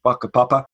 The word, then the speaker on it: whakapapa: Or in a Maori culture in New Zealand, there's a concept called whakapapa, which sounds a bit rude. It starts with a WH, but it's pronounced with an F, whakapapa.